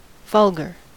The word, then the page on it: vulgar: American English